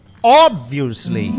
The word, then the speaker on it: obviously: A tua resposta óbvia é: domingo a essa hora? É obviously. Pronto, a partir daquele momento ela nunca mais errou a pronúncia de obviously. É obviously. Aí é só uma questão da gente ir dando uma calibradinha na pronúncia e ela chegou a obviously.